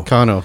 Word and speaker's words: Kano: Who's Mike Keno? – He's performing with you. Oh, Kano. – Kano, sorry. – Sorry, you said Keno. I'm like, who is Mike Keno? – Yeah, I said that wrong. Sorry, Mike. – Kano. – Mike Kano, yeah.